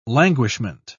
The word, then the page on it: languishment: British English